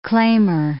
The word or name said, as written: claimer